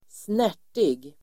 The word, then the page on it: snärtig: [²sn'är_t:ig]